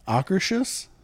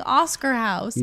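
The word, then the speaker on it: Akershus: How do you say it? – Ockershus? – I thought it was Oscar House this whole time. It's been 3 years and my brain has been saying Oscar House.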